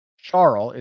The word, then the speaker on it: Schwartzel: Let's check Schwartzel. Charles is actually how you pronounce that, in case anybody was wondering.